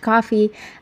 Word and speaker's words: Ko-fi: Side note, the website is actually pronounced coffee. It is spelled K-O-F-I, and our user for that is also Talking in the Movies.